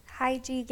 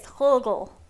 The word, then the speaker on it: hygge: Hygie game. That's where it's like a question game, which I don't think that's how you pronounce it. – It is Hulgel. – Our neighbor Hulgel next door to us told us how to pronounce it, and I don't think that was close.